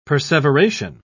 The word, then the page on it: perseveration: British English